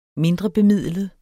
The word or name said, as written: mindrebemidlet